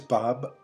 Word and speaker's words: Bob: My name is Bob Port, P-O-R-T. Yeah. Mm-hmm. Bob, B-O-B.